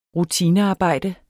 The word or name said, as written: rutinearbejde